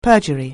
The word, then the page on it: perjury: anglais